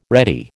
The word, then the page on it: ready: American English